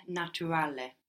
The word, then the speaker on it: naturale: So in Italian we're going to pronounce that U clearly like an U. – Naturale.